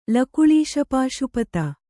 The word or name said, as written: ಲಕುಳೀಶಪಾಶುಪತ